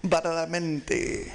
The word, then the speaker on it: Badalamenti: We started that off with the Twin Peaks theme by Angelo Badalamenti. – And I let pronounce that because... – Badalamenti.